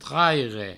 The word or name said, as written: traire